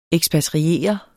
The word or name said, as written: ekspatriere